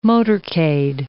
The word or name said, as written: motorcade